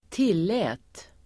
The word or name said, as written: tillät